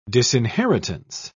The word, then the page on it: disinheritance: British English